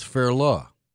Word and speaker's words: Fairleigh: According to Forvo, it's Fairleigh Dickinson. F-A-I-R hyphen L-U-H, Dickinson.